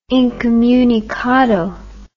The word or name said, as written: incomunicado